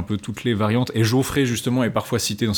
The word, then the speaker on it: Jaufré: Celle de la traduction de René Lavaux et René Nelly, dont eux, ils suggèrent que l'original devrait se prononcer Geoffrey.